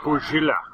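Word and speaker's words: Godzilla (ゴジラ): Well, he pronounces it Godzilla. – As in the proper Japanese way. – Yeah, exactly. Godzilla. Maybe that's the thing. – Maybe. – Well, there's no Z in the Japanese language, so...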